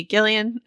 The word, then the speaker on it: Gillian: You know, I was reading, Hard G. Gillian Flynn gave a great interview to Megan Abbott in Vanity Fair.